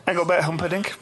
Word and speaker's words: Engelbert Humperdinck: Engelbert Humperdinck. 1968. – Say his name again, sorry. – Engelbert Humperdinck.